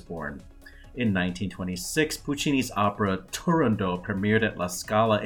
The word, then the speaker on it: Turandot: Turandot, the T's there. Puccini pronounced the T.